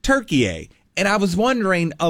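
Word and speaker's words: Türkiye: So Turkey-A is spelled T-U-R-K-I-Y-E. And there's those two dots over the U. All right? And it's pronounced Turkey-A.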